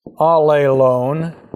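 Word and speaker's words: allelon (ἀλλήλων): That means this phrase in Greek, allelon. Okay, A-L-L, long E-L, O, long O-N. That means one another.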